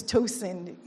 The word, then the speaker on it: Tosin: Like I shared in the morning service, my favorite is, because my name is Tosin, you get my name gets butchered and it comes out as Toxin is good.